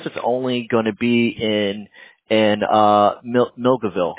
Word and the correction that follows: Milledgeville: Like, I think I'm saying that correctly, right? – Milledgeville. – Milledgeville. Okay, sorry.